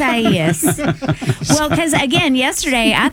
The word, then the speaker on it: Isaias: Isaias. You missed a syllable.